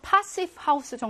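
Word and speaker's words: Passivhaus: 자, 이번에는요, 패시브 하우스래. 패시브 하우스가 뭔지 좀 읽어봐야겠지? 패시브 하우스인데, 뭐 독일어로는요, 파시브 하우스 정도로 발음해야겠다.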